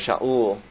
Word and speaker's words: Shaul: His real name was Shaul, and Shaul was a bit of a problem to Greek speakers, that they had a hard time pronouncing that. First of all, there was no consonant in Greek, so that's how we get Saul.